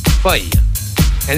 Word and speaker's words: fuyer: And that's spelled F-U-Y-E-R, fuya.